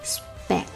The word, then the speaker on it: speck: Just a speck of.Just 跟 a 连起来 speck 这里有个浊化 sp 清辅音 s 跟清辅音 p 凑在一起的时候 p 浊化成 b